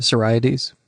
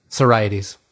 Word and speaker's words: Sorites: Sorites. – Sorites.